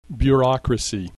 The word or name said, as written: bureaucracy